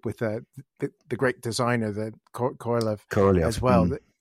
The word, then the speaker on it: Korolev: She kept calling him Korolev. He's spelled K-O-R-O-L-E-V, but K-O-R-L-Y-O-V is the way I see it in my brain. That's how I pronounce him.